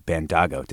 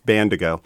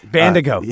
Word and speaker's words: Bandago: And we've got president of the San Francisco Small Business Commission and founder and CEO of Bandago. Did I say that right? – There's two corrections there, actually. One is it's Bandago. – Bandago.